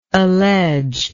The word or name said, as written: alleger